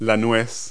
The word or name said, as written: walnut